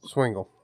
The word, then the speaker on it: Swingle: Swingle.